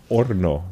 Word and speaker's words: horno: Orno. – Orno. – Silent H.